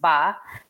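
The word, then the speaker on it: ba (八): The Cantonese word for 8 is pronounced ba. Sounds similar to the word which means prosper or wealth.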